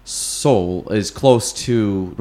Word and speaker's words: Seoul: It's actually pronounced Seoul. Two syllables. Seoul. – Seoul. – Seoul. But it's written Seoul. S-E-O-U-L. So Seoul. So it's pronounced Seoul in English, like your soul, spirit. Soul. – Yep. – But it's supposed to have two syllables. Seoul.